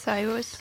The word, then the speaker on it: Cyrus: Cyrus.